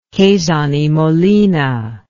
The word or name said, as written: Quezon y Molina